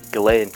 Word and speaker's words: Ghislaine: How the fuck do you pronounce that lady's name? – Ghislaine Maxwell. – Ghislaine? – Ghislaine, yeah.